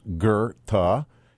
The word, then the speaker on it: Goethe: That's why where it comes from, but it's pronounced Goethe, even though it's G-O-E-T-H-E. It's German, obviously, pronunciation.